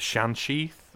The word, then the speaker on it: Shansheeth: It's like the uh, I'm not going to pronounce this right, Joe, but the Shansheeth in Sarah Jane. – Adventures. No, you got it. – You got it spot on.